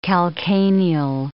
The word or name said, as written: calcaneal